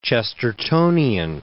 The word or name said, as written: Chestertonian